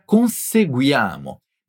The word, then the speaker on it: conseguiamo: Una frase conseguiamo questo risultato con i nostri occhi puntati saldamente sul futuro, embora sia praticamente uguale na forma e no significato, a palavra corrispondente in português apresenta una pronuncia che talvez seja un poco complicata no começo. Conseguiamo. Pois aqui tem 3 vogais juntas, uia, e se achar un poco difficile pronunciar o grupo guia, se focalizem primeiro na pronúncia certa desse grupo e somente depois adicione o resto da palavra.